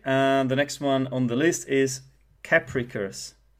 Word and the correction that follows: capricious: Capricus. – Capricious? – Oh, damn, I mispronounced it.